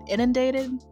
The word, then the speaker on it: inundated: Oh, I always say this wrong. And did I say that right? Inundated? – Yeah.